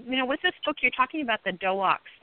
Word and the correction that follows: Doxa: DOXA. No, it's DOXA. D-O-X-A. D-O-X-A.